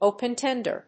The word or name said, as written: open tender